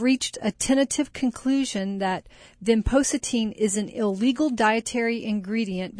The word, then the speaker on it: vinpocetine: Vinpocetine. – Vinpocetine is a derivative of the periwinkle plant.